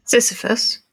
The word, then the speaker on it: Sisyphus: Sisyphus, am I pronouncing it right? The Greek myth of Sisyphus.